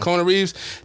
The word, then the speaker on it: Kona Reeves: Kona Reeves, am I saying it right? Mm-hmm. Kona Reeves, that was a guy they brought in.